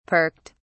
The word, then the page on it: perked: British English